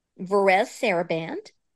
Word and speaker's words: Varèse Sarabande: Varèse Sarabande.